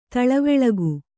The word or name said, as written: ತಿಳಿವಳಿಕೆ